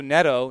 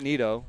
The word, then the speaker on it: Neto: And then also Neto, not Nito, Neto.